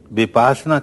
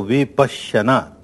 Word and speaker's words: विपश्यना: विपासना कहना बिल्कुल गलत है, संस्कृत में समझे तो विपश्यना,